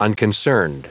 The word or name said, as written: unconcerned